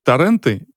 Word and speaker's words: торренты: Торренты или торренты? – Проверяем. – Торренты. – Хуенты или Хуёрнты. – Хуёрнты. Торренты, хуёрнты. Значит, торренты.